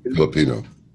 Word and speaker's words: Filipino: Also, the photo of his Philippian, what do you call it? Philippian girlfriend? Is that how you say it? – Yeah, Filipino.